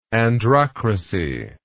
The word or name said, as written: androcracy